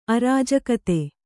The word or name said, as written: ಅರಾಜಕತೆ